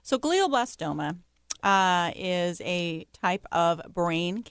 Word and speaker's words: glioblastoma: what is glioblastoma? – And Kim said it right.